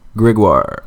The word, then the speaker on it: Gregoire: Chris Gregoire? Is that how they say it when Anglophones say it? – Gregoire. – Yeah. Do they say, hey, Chris Gregory? – Oh, all the time.